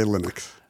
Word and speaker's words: Linux: Except now it uses a Linux base or Linux. How do you say it? – We say Linux.